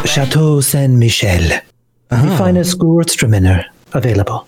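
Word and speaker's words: Gewürztraminer: It's pronounced Gewurztraminer. That rhymes with Hertzmowiener.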